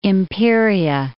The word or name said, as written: Imperia